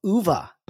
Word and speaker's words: Oova: Amy Devaranya is the founder of and the CEO of Uva.